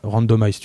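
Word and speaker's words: RNDMIZ2: Alors ça s'écrit RNDM IZ2, e, mais en fait c'est random, random, randomize, tu vois, ça se dit comme ça. Je te donnerai le compte Instagram.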